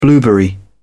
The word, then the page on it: blueberry: British English